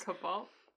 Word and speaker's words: Kabbalah: I no longer study Kabbalah. – Kabbalah? – Kabbalah. Shit, we always forget how to pronounce this. – Kabbalah. – My baby is my religion.